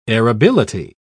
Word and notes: arability: British English

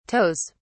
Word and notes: toes: British English

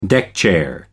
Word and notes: deckchair: American English

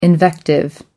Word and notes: invective: American English

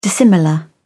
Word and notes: dissimilar: British English

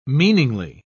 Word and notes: meaningly: British English

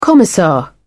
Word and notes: commissar: British English

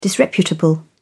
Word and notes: disreputable: British English

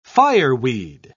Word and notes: fireweed: British English

